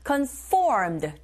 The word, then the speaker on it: conformed: 느낌으로 해주시고 conformed 뒷부분에 강세 있습니다.